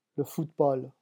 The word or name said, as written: le football